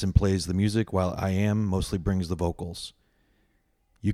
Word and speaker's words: EYEAM: How else can you explain the partnership and collaboration of Atlanta musicians IAM and Joel Hoffman? IAM, pronounced like I am, but spelled out E-Y-E-A-M, is a 20-something-year-old African-American hip-hop and R&B artist from the South.